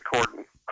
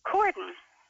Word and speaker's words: Corydon: I tell people it's the Y is silent. You know, it's just C-O-R-Y-D-O-N, but we pronounce it Cordon. – Cordon.